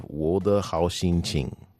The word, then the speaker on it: Wo De Hao Xin Qing: And finally, we have Wo De Hao Xin Qing. – Nice. That's very standard pronunciation of it.